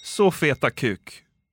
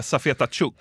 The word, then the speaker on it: Safeta Chuk: och hälsar på hos Så feta kuk. Ja, det... Man ska nog säga Safeta Chuk.